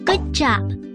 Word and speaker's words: Good job: Vu Va Gua，英语的辛苦，咱会当讲 Good job，读起来亲像骨架。